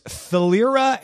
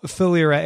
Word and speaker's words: Philyra: Last October, IBM announced Thalyra AI. It's spelled P-H-I-L-Y-R-A.